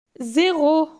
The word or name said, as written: 0